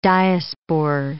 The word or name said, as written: diaspore